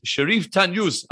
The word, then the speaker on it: Shariff Tanyous: So, joining me today on the podcast is Shariff Tanyous. Am I pronouncing your name correctly? Yes.